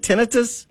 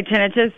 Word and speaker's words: tinnitus: Can arthritis cause tinnitus? Do you pronounce it tinnitus? – I say tinnitus. A lot of people say tinnitus. It really doesn't matter.